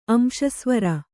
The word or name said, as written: ಅಂಶಸ್ವರ